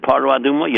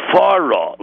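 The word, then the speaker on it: fara (פרה): Do you know that the first word, the time the word paraduma is mentioned in the pasuk, you do not pronounce it paraduma, you know that? Because in the Torah it did not put a dogesh. It's fara, like F-A-R-A, fara aduma.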